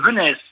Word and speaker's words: Venez: Donc on prononce le Z. – On prononce et le Z. – Et ça s'écrit comme le verbe venez. On prononce le Z. – Donc, et vous le prononcez Venesse, avec un peu de voilà. S.